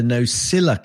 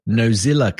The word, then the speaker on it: Nosilla: And if you're listening, uh, Allison, you'll hear that I said that correctly because so many people go Nozilla cast, a little bit like Streisand. well, it hasn't got a Z in it, folks, and that's what she's complaining about.